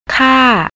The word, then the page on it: kar: thai